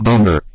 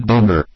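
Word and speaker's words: Boehner: John Boner. – Thank you. I'm sorry, say again? – John Boner. – Thank you. When confronted with the Tenther question, Boner offered an entirely... – John Boner.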